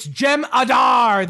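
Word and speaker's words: Jem'Hadar: It's Jem Adar. The H is silent.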